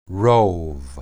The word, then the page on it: rob: hebrew